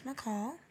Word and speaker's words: Michal: At the name's McCall. Period. And McCall is spelled M-I-C-H-A-L.